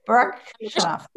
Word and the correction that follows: Baryshnikov: Baryshnikov. – Baryshnikov in White Nights.